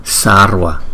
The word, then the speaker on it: sarva: Đó, chữ S này không có dấu sắc, đọc là sarva, sarva, sarva.